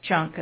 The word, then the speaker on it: Junque: But you have to, when you see the name Junk in the Trunk, you have to know that this is a classy operation. So it is spelled J-U-N-Q-U-E. – That's why I said junk.